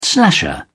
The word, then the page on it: slasher: British English